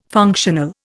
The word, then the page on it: functional: Indian